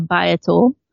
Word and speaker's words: Biotool: And then that's not my accent, by the way. That is how I do pronounce bio, but but it is a BIA tool, so a Biotool.